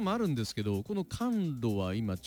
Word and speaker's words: 寒露: 二十四節気の一つ、昨日 10 月 8 日が寒露だったんですね。寒いに梅雨と書いてね、寒露。